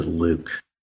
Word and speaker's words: LIWC: So working with one of my students, Martha Francis, we built a computer program called Linguistic Inquiry and Word Count, LIWC, and we pronounce it Luke.